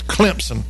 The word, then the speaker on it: Clemson: Got to say it right. It's Clemson. And he was there, I'll tell you why, in our entertainment headlines of this final hour.